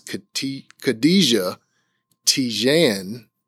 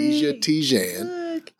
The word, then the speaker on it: Khadija Tejan: And with me today, I have Ms. Khadija Tejan. Did I say that right? – You got it. You got it. – Khadija Tejan.